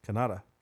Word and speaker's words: Kanata: Okay, how would you pronounce Kanata? – K-A-N is Khan. – Yes. – A-T-A? – Yes. – Kanata. – Kanata? – Yeah.